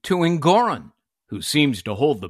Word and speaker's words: Engoron: Engoron, which rhymes with moron, didn't care.